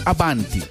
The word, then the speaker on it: avanti: O sea, corriendo al señor como para que lo tenga presente, cuando usted se refiere de esa manera, no se dice vamos avante, se dice avanti, porque no es una expresión.